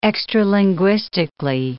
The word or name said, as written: extralinguistically